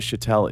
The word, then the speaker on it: Shettel: So by the time you guys are hearing this out there, Travis SHETTEL is famous. Is that how you say your last name? – SHETTEL. – SHETTEL. – Yeah. SHETTEL. – Cool. – Like a shuttle.